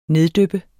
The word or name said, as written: neddyppe